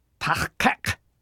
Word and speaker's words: Tach-kak: Tach-kak! There's a new sound at both the beginning and end of the second syllable, kak. Be careful. This is not the Federation standard K sound.